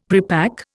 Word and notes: prepack: Indian

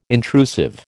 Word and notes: intrusive: American English

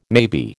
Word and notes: maybe: American English

